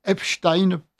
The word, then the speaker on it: Epstein: Alors, une auditrice nous dit, merci madame d'ailleurs, je suis germanophone et je peux dire que le nom de cet abominable individu se prononce Epstein, prononciation allemande.